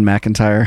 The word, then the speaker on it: McIntyre: On one side, did I say his name right? – Aaron McIntyre. – No, I don't think that's right. – His last name is McIntyre. – Oh, sure. – I thought it was McKinte. And you know,